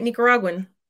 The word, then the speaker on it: Nicaraguan: So I'm wondering, as a K-12 child, having the heritage that you had, being proud of being Nicaraguan, how do you say it? Help me out. – You said it. No, you said it right. Nicaraguan.